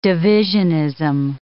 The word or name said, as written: divisionism